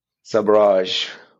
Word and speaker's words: sabrage: So, Sabrage, in English Oxford Dictionary, it was pointed out to me at an event in London that this is how you say it in English properly. Sabrage.